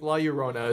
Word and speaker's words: La Llorona: La Llorona. Yeah. La Llorona. – Yes. There you go. Okay. But you can you can say it like La Llorona as well too.